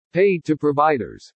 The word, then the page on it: PAID TO PROVIDERS: English